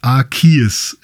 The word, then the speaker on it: Arceus: Aber ähm, ihr könnt sicher sein, es heißt Arceus im Englischen und Arceus im Deutschen. So wird es ausgesprochen.